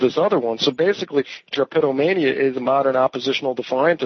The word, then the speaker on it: drapetomania: Drapetomania.